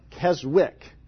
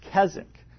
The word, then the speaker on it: Keswick: It looks like Keswick, but it's pronounced Keswick.